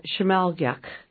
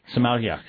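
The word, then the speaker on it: Sm'algyax: And then tell me how we pronounce the mini immersion class. – It's a Shimalgyak. – Shimalgyak. – And that is the language of the Tsimshian people.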